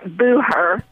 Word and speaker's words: Booher: Most people can't understand that silent H in there, so I'll spell it as Booher, like Booher.